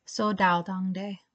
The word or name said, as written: Sodao Dangde